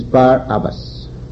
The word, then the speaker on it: Barabbas: And its correct pronunciation is Barabbas, son of the devil father.